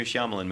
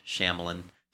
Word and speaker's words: Shyamalan: Like before I watched the trailer, I saw it was like new Shyamalan movie. By the way, I say it's the Indian way, not the English Shyamalan. – How do you say it? – Shyamalan.